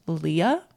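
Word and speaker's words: Lia: Leah. So short for my name, Celia, it's spelled C-I-L-I-A.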